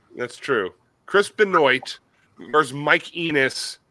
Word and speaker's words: Benoit: Benoit. – I heard he lost a wrestling match to a gym machine. – Benoit? – I don't know how you get Wah out of O-I-T. – How do you get Wah out of R-O-Y? – That's Roy.